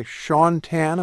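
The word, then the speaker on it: Shaun Tan: It's called The Arrival by Sean Tan.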